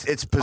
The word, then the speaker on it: Pizano: Spanish, it would be Pizano or Pizano. – Yeah. – Because the I is pronounced.